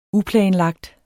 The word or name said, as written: uplanlagt